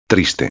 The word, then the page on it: triste: espanhol